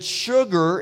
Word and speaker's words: sugar: did you know that sugar is the only word that's spelled S-U but pronounced S-H? Sugar, but it's spelled S-U.